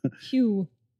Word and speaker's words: Hugh: Hugh. – Rhymes with pew.